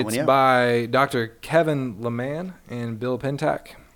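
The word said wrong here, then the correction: Leman: Kevin Lehman is pronounced. Lehman. – Yep. Kevin Lehman. Lehman.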